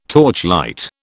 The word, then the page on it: torchlight: British English